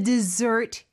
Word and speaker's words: dessert: D-E-S-S-E-R-T S가 두 개 들어갑니다, mm-hmm. 여러분. S 하나만 쓰시면 안 돼요. 그러면은 사막이라는 뜻이 되죠. – Desert. – 네, 발음도 다르고요. The dessert here is to die for.